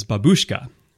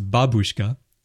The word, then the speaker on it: babushka: So for example, I'm sure you guys know the word for grandmother in Russian, which Americans say is babushka, but actually it's babushka, so the stress is on the front.